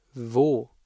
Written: wo

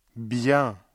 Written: bien

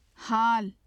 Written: haal

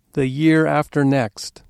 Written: the year after next

